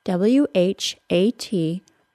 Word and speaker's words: what: Is the word what, W H A T, pronounced as what or what? The short and simple answer is to pronounce the word as what with no H sound. However, this is not a universal American pronunciation. Some dialects of American English, spoken in the southeastern part of the United States, do say what, but that pronunciation is significantly less common.